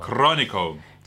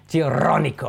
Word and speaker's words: Chironico: But he did a climb called Poison the Well, which is 8C+ in Chironico. I think it's Chironico. You got to roll your R's. Chironico. – Chironico.